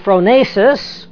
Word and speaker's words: phronesis: And the understanding then is the phronesis, P-H-R-O-N-E-S-I-S, long E there.